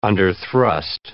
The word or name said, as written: underthrust